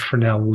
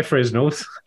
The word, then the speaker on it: Fresnel: I have to congratulate you, on the correct pronunciation of Fresnel lens. – Why, thank you. You expected me to say a Fresnel lens. – Yep, I've heard plenty of people say Fresnels.